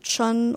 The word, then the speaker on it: Chin: And we don't know if Chin, spelled C-H-I-N-N, is really our last name, because he used C-H-I-N, but Americans pronounce it Chun, or there's different intonations, so we think it's spelled C-H-I-N.